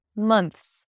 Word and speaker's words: months: Months. It's not months, all right? There is no O sound there, although it's spelled with an O. It's Months. Then we have N-T-H-S, mth, mths.